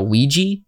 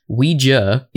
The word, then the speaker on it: Ouija: So the hilarious thing about, I don't know why the common pronunciation of this is now Ouija, because Ouija is a made-up word. It literally isn't a real word.